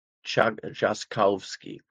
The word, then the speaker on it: Trzaskowski: He's got an RZ in the name, which I believe is pronounced like a J. Shall I try it again? Trzaskowski.